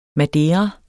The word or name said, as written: madeira